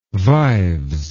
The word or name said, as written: vives